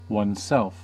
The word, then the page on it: oneself: American English